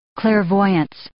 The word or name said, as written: clairvoyance